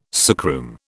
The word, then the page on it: sickroom: British English